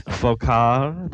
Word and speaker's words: Foccart: It's Foccart. The T is silent. It is France.